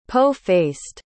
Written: po-faced

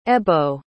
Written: ebbo

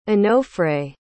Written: onofre

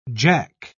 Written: jack

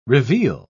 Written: reveal

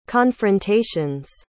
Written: confrontations

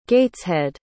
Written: gateshead

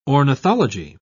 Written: ornithology

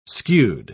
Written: skewed

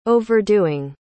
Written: overdoing

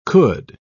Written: could

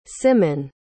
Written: Symon

